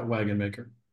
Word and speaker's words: Wagenmaker: Yeah, Wagenmaker.